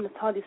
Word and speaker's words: Nataylia: Natalia spelled with a Y, N-A-T-A-Y-L-I-A, R-O-N-I.